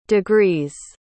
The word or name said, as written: degrees